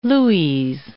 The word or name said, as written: lues